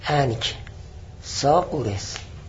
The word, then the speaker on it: انکساغورس: و انهو یعنی انکساغورس، کاف انکساغورس ساکنه، کاف ساکنه، ساکن تلفظ میکنم، حس میکنی تلفظ نشد، انکساغورس.